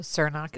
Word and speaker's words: Cernoch: So Cernoc is spelled C-E-R-N-O-C-H.